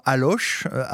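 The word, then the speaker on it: Allauch: D'ailleurs, sur Mélodie TV, nos amis vont voir des images tournées dans la ville d'Allo, Alloche.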